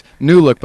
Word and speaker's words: NuLOOQ: Okay, it's New Look, but it's spelled N-U-L-O-O-Q. So it's, you know, N-U-L-O-O-Q.